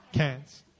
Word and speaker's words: Cairns: Cairns? Cairns.